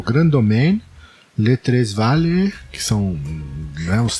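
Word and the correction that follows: Les Trois Vallées: Como é que fala Les Tres Valles? Em... Primeiro deve ser Les Trois Valles, né? – É, Les Trois Valles, muito bem.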